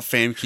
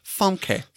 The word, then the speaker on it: Famke: No, it's not Famke, you Aussie twat. It's Famke.